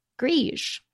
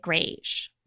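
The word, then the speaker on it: greige: And I guess you could say it grage, but I've never heard that. – No, I've never heard it pronounced that way. I've always heard grige. – But Lisa, we say, you say grage, we say grige.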